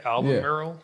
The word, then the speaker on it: Albemarle: Is that how you say it? Albemarle? – That looks right. Albemarle.